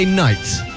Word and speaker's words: knight: Who is going to be my Friday knight? And that is Friday night with a silent K.